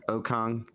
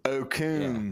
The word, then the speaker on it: Okung: Russell, is it Okung? – Okung. – How do you say his last name? – Okung.